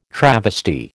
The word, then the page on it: travesty: American English